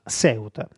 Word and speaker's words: Ceuta: chiedo scusa, ho detto ancora una volta Ceuta, ma Ceuta, la pronuncia spagnola.